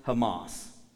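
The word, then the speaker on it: Hamas (חמס): But this word, because of the CH, makes a sound that's actually pronounced Hamas.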